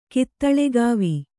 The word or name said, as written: ಕಿತ್ತುಳಿಗಾವಿ